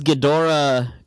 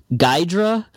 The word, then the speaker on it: Ghidorah: because in one verse he calls Ghidorah Geedorah. And then in the next verse, he says King Geedorah.